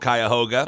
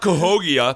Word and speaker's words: Cuyahoga: It's called Cuyahoga. And I was going to be like, no, it's not Cahogia, or whatever you were going to say. It's Cuyahoga Falls.